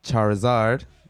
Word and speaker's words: Charizard: We got Charmander, and then we got Charizard.